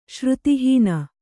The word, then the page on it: ಶ್ರುತಿಹೀನ: śruti hīna